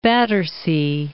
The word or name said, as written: Battersea